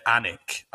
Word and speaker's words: Alnwick: So the goalkeeper for Spurs on the night was Ben Anik, I think is how you say the name. A-L-N-W-I-C-K, which is about as weird a pronunciation as you can possibly get.